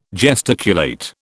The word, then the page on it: gesticulate: British English